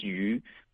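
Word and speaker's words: yu (鱼): Sí, es un plato imprescindible casi en cada familia, porque en China el pescado en mandarín su pronunciación es yu. Y es igual como la palabra de abundancia,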